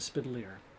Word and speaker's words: Spidelier: and my last name is Spidelier. Now, there are very few people who can pronounce the last name Spidelier, which is Italian in origin. And, you know, until you say it rhymes with chandelier, most people don't get it, and they struggle with it.